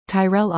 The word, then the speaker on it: Tyrell: Tyrell Osborne, pronounced like Carol with a T, with Seeker Creative was a shy child who found her voice through art.